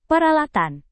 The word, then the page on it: peralatan: indonesian